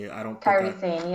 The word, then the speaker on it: Kairi Sane: Kairi Sane is now, I don't know if I even said that right. Please correct me. – Kairi Sane, yes.